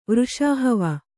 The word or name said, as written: ವೃಷಾಹವ